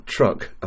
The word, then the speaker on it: Chuuk: Keith GM4YXI and Chris GM3WOJ will be active as Victor 6 Zulu from Truk, I'm told it's pronounced, Island in Micronesia. It's spelt CHUUK, IOTA reference OC011 until the 1st of November.